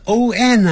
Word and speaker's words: on: 很多人念成 on，你念成 on 的话就变成 ong 了，有 g 的话才会念成 on，那个鼻音出来，没有 g 啊，所以这个时候呢是 on 啊，所以不要再发，从黑龙江一直到海南岛，我们都是念成 on，不要念成这样念，念安全的安 on，有的那个外国人嘴巴更稍微圆一点 on 都可以。